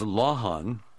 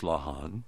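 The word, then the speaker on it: Lawhon: Paula's website, in case you want to see something on her website, is Lahan. That's L-A-W-H-O-N, pronounced Lahan.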